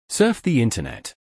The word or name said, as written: Surf the Internet